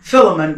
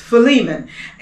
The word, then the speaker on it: Philemon: I know some pronounce it Philemon, but it's Philemon.